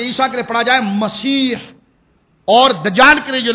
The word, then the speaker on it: مسیح: اس کے ساتھ حضرت عیسیٰ کے ایک لقب آتا ہے مسیح, لقب آتا ہے مسیح, اور دجال کے لیے بھی لفظ آتا ہے. لیکن بزرگوں نے اس کے تلفظ میں فرق بیان کیا کہ حضرت عیسیٰ کے لیے جو مسیح